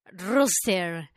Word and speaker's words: rooster: Dice, saludos al roster, o sea, al gallo, por los que no saben inglés. ¿Cómo se pronuncia? Rooster.